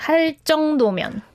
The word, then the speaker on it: 할 정도면: In the second sentence, there is 정도면, which is pronounced like 정도면, like 할 정도면. – With the 할 before it, it kind of influences the 정. So 할 정도면.